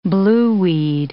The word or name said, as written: blueweed